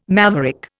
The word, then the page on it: maverick: Indian